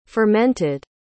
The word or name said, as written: fermented